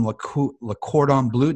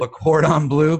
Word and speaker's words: Le Cordon Bleu: He has a degree in business, a master's degree in business administration from Penn State, an advanced culinary degree from Le Cordon Bleu. Did I say that right, Rob? – Yes, you did. – Le Cordon Bleu, Pittsburgh, PA.